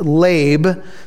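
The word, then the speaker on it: lab (לב): It's called leb or lab, and it's spelled in English L-E-V, like lev, or L-E-B, leb. It's actually pronounced lab. So if you want the phonetic pronunciation, you would spell it L-A-B-E, lab.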